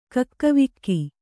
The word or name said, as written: ಕಕ್ಕಾವಿಕ್ಕಿ